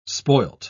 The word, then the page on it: spoilt: British English